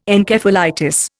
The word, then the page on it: Encephalitis: Indian